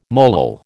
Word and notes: molal: American English

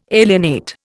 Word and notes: alienate: Indian

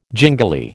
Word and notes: gingili: American English

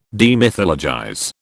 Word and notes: demythologize: British English

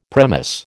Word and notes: premiss: American English

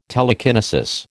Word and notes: telekinesis: American English

